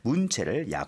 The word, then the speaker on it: 문체: 제가 아까 실수로 읽다가 문체를 문제라고 읽었어요. 그러다 보니까 뒤에 약화가 아니라 악화시킨다는 건데 문제를 하면서 저도 모르게 이렇게 좀 실수를 했습니다. 죄송합니다. 그래서 문체를 약화시킬 수 있다라고 경고를 했습니다.